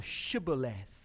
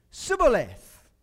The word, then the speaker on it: Shibboleth: The Ephraimites cannot pronounce Shibboleth. So the Gileadites come up with this test for identification, and as an Ephraimite is coming from the battle, they say to him, "Are you an Ephraimite?" And when he says no, they say to him, "Well then say Shibboleth." And he says, "Sibboleth." Can't pronounce it.